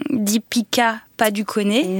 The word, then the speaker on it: Deepika Padukone: Deepika Padukone. – Oui. – Est-ce que c'est la bonne façon de le prononcer? – Oui, oui.